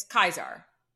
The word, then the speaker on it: Caesar: It's Kaiser. That's how you say it in Latin.